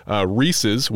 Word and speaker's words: Reese's: One, I was in the grocery store yesterday, and they had those Reese's, which is the proper way of pronouncing it, pumpkin.